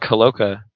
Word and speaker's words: Holowka: Mr. Alec Holowka, a.k.a.